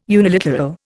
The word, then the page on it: uniliteral: Indian